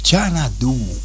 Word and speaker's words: Xanadu: Bueno, ahí teníamos Xanadu. Tal cual, se le dice así y se pronuncia así.